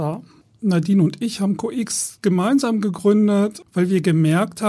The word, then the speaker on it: CO:X: COX. COX, ja.